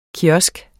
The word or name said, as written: kiosk